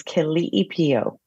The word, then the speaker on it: Keliipio: The Hawaiian pronunciation is Keliipio.